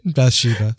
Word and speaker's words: Bathsheba: Bathsabi? – Bathsheba, yeah.